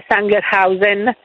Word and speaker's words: Sangerhausen: si legge Sangerhausen.